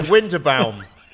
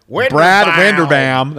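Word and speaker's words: Winderbaum: I keep pronouncing his name as German. Sorry, Vinderbaum. I keep going, Vinderbaum. – Do it with an American accent. Brad Vinderbaum.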